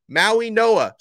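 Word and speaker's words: Mauigoa: No, I'm pronouncing it right. You learn how to pronounce his name. Maui Noa. So the Maui Noa brothers, that's how you say their last name.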